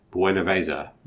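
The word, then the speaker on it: Buenaveza: The one that I'm particularly looking forward to is Stone's Buena Vesa. Am I pronouncing that correctly? – Yeah.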